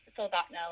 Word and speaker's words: Soldotna: But it's Soldatna, Alaska.